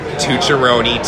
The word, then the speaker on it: Tucciarone: You might have to search me in YouTube under my full name, which is Matthew with 2 T's, Paul Tucciarone, TUCCIARONE.